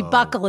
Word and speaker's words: buccal: I didn't realize the buckle was B-U-C-C-A-L. Which I didn't either, actually. – Yes, which I always thought was buccal, how you said that, but it must be buckle is how you pronounce it.